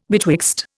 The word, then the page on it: betwixt: Indian